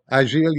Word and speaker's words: Agile: And it was something called, do I say this right? A Lapin Agile? – Agile, yes.